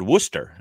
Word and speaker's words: Worcester: Well, you're talking to someone that a New Englander where, you know, where the Red Sox AAA team is in a town called Worcester, and it's pronounced like rooster, but with a W instead of an R.